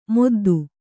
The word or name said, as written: ಮೊದ್ದು